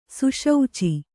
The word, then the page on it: ಸುಶೌಚಿ: Kannada